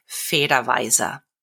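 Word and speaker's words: Federweißer: Federweiser. It is spelled Federweisser.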